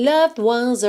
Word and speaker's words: loved: Esse E de loved não é pronunciado, tá? E o D você pode, inclusive, usar para ligar no ones. Só que você tem que ir do V direto para o D.